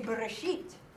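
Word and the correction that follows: Bereshit (בְּרֵאשִׁית): Brashit. Okay? Here. Am I doing? Oh, no. Bereshit is. Bereshit. There you go. Bereshit. Okay, this one, you pronounce the quick eh or the short eh. Bereshit.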